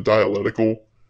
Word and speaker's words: dialectical: So the next antinomianism that Packard dives into is the dialectical. Make sure you pronounce that first C there.